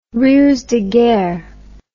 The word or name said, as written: rusedeguerre